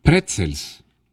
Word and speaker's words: pretzel: Pretzels. – Pretzel. – Pretzels. – Pretzel. – Eso, la T y la Pretzel. – Z. La T pegada a la Z.